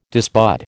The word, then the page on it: disbud: American English